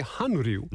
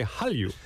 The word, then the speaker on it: Hallyu: And this was a pronunciation note I learned, because it's, I mentioned before, the characters are Han and Ryu, but you don't say Han Ryu. – No. – That's too hard. You say Hallyu.